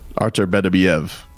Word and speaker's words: Artur Beterbiev: Better Beeve offers a title shot to Jake Paul. Is that something? – Not how you say his name. – Artur Beterbiev? – Artur Beterbiev.